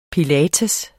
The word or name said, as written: pilates